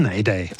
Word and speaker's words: Neidei: Da wäre zum Beispiel geschrieben ne Idee, ausgesprochen das Neidei.